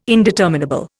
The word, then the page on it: indeterminable: Indian